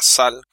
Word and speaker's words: Zhal: Está ambientado en un mundo que se llama Zal, que se escribe Zhal,